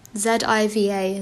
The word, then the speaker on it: Ziva: Z I V A. Ziva? Am I saying that right? Yeah. Ziva.